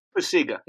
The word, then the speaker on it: Pacyga: Pacyga, yeah.